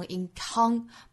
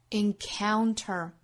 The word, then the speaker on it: encounter: We encounter 这个词不要读成 encounter，不是 con 哈，要注意双元音的滑动，从 a 滑动到 on，再带出小鼻音来 encounter。